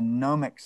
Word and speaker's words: gnomic: Yes, G-N-O-M-I-C, gnomic. And we're not talking about little forest creatures from Scandinavian mythology, not that kind of gnome.